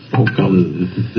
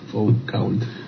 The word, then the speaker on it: Foucault: Panóptico Fucal. – Sí, acá está, mirá. Panóptico de Foucault. No sé cómo se dice. – Foucault. Está bien. Foucault.